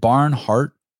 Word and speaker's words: Barnhart: Is it Barnhart? – Barnhart. – Barnhart. – Yeah. – Okay. Like two words, like a barn, like that, like owls live in, and heart, like that thing in behind my rib cage.